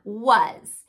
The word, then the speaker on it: was: Now, was is going to be a little bit different because it does have S representing the Z sound, but the vowel is representing even though it's spelled with an A.